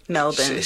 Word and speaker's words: Melbourne: Melbourne. Say it right, Melbourne.